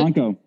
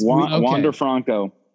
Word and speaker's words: Wander Franco: Franco. – Yes. – Wander Franco.